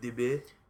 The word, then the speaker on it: daube: So I'd say Yeah, that beef stew, the French daube, or daube, or however the hell you pronounce it. I've only read it on paper. I've never heard like a French person talk about it. I just found daube. – The recipe. – Daube.